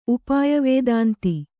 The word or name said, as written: ಉಪಾಯವೇದಾಂತಿ